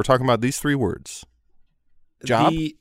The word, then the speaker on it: Job: They are pronounced differently when the first letter is capitalized. Job becomes Job, polish becomes Polish, and herb becomes herb.